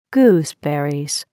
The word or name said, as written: gooseberries